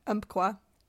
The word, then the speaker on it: Umpqua: Her decomposed body was later found along the, oh my gosh, I'm going to say that wrong. Umpqua River? – Umpqua.